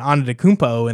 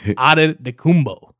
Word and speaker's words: Antetokounmpo: Like, I was assuming you'd come in with like an Antetokounmpo, and they just like, that's actually not how you say it. Antetokounmpo.